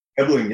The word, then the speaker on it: Ebeling: Ebeling, yeah, you got it right.